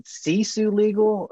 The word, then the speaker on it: Sisu Legal: But do you pronounce it Sisu Legal, or how do you pronounce it? – Yep, you nailed it.